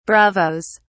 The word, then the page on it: bravos: British English